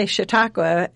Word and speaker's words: Chautauqua: We actually say Chautauqua, and it's a U in every syllable.